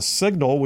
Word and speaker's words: Cygnal: And then from Montgomery, got picked up to work with Signal, which is spelled C-Y-G-N-A-L, Signal Polling and Analytics.